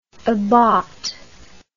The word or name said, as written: abaht